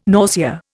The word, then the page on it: nausea: Indian